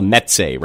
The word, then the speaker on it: mezze: and it's kind of what they call a mezze, right? – Mezze, that's how you say it.